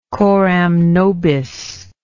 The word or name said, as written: coram nobis